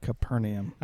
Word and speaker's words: Capernaum: most people pronounce that Capernaum.